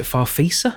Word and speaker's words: Farfisa: So I think the first thing I played on when we got to the studio was is it Farfisa? Have you heard of? – Yeah, that will do as a pronunciation.